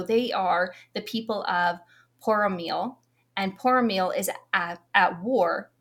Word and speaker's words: Poromiel: Poromiel.